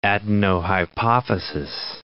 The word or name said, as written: adenohypophysis